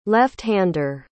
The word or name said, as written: left-hander